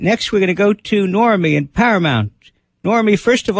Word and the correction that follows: Noemi: It's actually Noemi, but it's okay. – All right. Noemi, how are you tonight? – I'm fine.